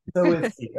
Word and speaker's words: Soinski: Soinski.